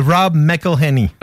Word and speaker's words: Rob McElhenney: C'est Rob McElhenney.